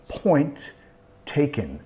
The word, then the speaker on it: point taken: Y nota que no voy a pronunciar las dos T's. Tenemos una T al final de point y después una T con taken. Point taken. Point taken. Estoy vinculando, solo voy a pronunciar una de las T's.